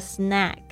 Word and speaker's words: snack: 有很多同学这个发音傻傻分不清楚哦。这个 snack s n a c k，这个 a 发 a 的声音，但是呢 snake。